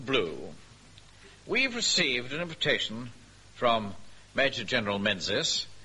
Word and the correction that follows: Menzies: Mengies. – Yeah, I beg your pardon? – It's pronounced Mengies.